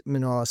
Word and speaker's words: Minaw: Pronounced Minaw, spelled M-I-N-A-W.